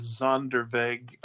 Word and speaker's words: Sonderweg: And unfortunately, they're developed, and I think you pronounce this in German as Sonderweg.